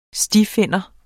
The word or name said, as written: stifinder